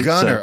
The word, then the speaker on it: Gunter: We don't pronounce our T's. I'm Gunter, basically. – Gunter.